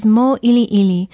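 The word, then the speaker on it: Mo'ili'ili (Mōʻiliʻili): Another of those beautiful Oahu Hawaiian place names so often mispronounced is Mo'ili'ili, a residential area near Manoa. It is spelled M-O-Okina-I-L-I, Okina-I-L-I, and it has a makron or kahako over the first vowel, the O.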